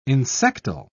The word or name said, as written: insectile